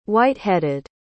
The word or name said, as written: white-headed